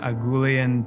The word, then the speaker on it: Agoulian: Now, I'm delighted to welcome Rita Agoulian to the show.